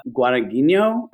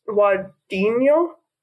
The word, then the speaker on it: Guadagnino: I, I think it's Guadagnino. I don't know. I'm bad at it too. it's it's that GN, like that nya sound.